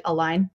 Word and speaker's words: A-LIGN: Um, Myles, can you tell us a little bit about what you do with A-LIGN? Am I saying it right? A-LIGN? – Yeah, you are.